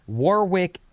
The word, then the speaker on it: Warwick: Vinny, Warwick, England is pronounced with a silent W in the middle, more like Warwick.